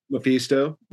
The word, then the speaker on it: Mephisto: is it Mephisto? Is that how you say it? – Mephisto.